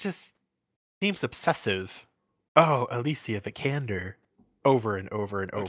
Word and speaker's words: Vikander: No, it is Vikander.